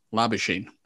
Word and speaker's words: Labuschagne: Now, this is actually, this is an interesting debate because I believe, well, everyone pronounces his name as Marnus Labuschagne, but it is spelt Labuschagne. So which one is it? Which one is it? Is it Labuschagne or Labuschagne? – It's definitely Marnus Labuschagne.